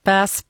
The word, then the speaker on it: bass: Innan jag pratade med schackdomaren här i Uzbekistan så spelade jag Miley Cyrus Mileys nya låt då, som jag uttalade Bass. Bass Perseids. Eller man säger tydligen bass såklart, skriver Alexius. Bass. It's all about that bass. Inte bass. Förlåt.